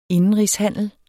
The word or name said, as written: indenrigshandel